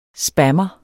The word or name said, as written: spammer